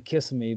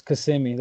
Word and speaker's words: Kissimmee: I always say Kissimmee, but I know that's not right. It's Kissimmee.